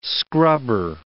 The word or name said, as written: scrubber